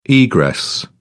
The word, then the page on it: egress: British English